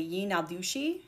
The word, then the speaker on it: Yinadushi: The new witch is now called a Yinadushi, and I looked up how to pronounce that, so I did get it right, because he can now become an animal and walk on four legs.